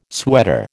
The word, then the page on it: sweater: American English